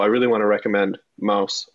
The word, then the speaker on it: Maus: Art Spiegelman was actually speaking, not on campus, not physically on campus, but like to Central Michigan students, about his work, Mouse, M-A-U-S is how you pronounce it, which is like a comic book about the, like his father's story of living through a concentration camp and the Holocaust.